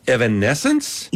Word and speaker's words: Evanescence: Evanescence? – Yes, there you go.